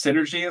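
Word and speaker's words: Psy-Energy: I believe it's synergy is how it's supposed to be pronounced. It's spelled Psy Energy, PSY meaning.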